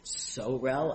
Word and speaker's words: Sorel: So I think that the way that appellant pronounced it is they said that their term sorel rhymes with morel, like the mushroom.